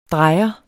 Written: drejer

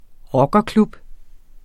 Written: rockerklub

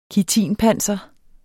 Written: kitinpanser

